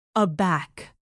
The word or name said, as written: aback